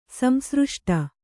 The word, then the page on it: ಸಂಸೃಷ್ಟಿ: samsřṣṭi